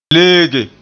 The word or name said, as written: leege